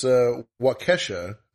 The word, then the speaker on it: Waukesha: I mean, first-time offenders like, for this, Waukesha is how it's pronounced.